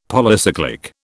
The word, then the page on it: Polycyclic: British English